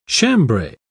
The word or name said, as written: chambray